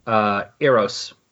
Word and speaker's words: Eros: Eros.